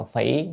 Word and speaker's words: phỉ: Đây thì xin đọc âm: túng tha bán nhậm tha phi, nhậm tha phỉ, cái này đọc nên đọc là phỉ nhậm phi.